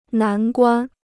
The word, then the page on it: 难关: nán guān